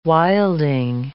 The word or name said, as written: wilding